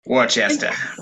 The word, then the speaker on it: Worcester: I almost wanted to pronounce it like Worcestershire. – Worcester.